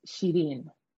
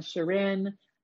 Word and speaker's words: Shireen: I don't have a lot of attachment to the pronunciation because the actual pronunciation in Farsi is Shireen. And it's tough. It's like, it's a mouthful for people to say. So growing up when I was in Canada, everyone said Shireen, but I also like Shireen. Some people say Shireen.